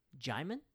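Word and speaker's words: Djimon: But I think the proper pronunciation of his name is Jaimin.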